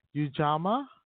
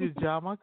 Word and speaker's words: Ujamaa: She joined, and hopefully I'm saying this correctly, Ujamaa Construction. – Yes. – Did I get that right? Okay. – You did. – Oh, thank you. She joined Ujamaa Construction in 2019 as a project engineer.